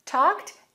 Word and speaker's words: talked: Talked and texted both have ED endings, but they're pronounced very differently. Let's say talked, talked. That ED sounds like a T sound, talked, talked on the phone.